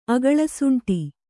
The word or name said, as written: ಅಗಳಸುಂಟಿ